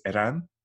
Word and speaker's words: Eran: it's not really Ronnie either. It's Eran, but mm like mm-hmm. nobody can pronounce it.